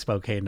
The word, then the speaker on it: Spokane: Or is it Spokane? I just got a lesson on this not long ago. I was saying it wrong, right? So it's Spokane, I believe. I was quickly corrected. I'm from Massachusetts. It looks like Spokane to me, but it's Spokane.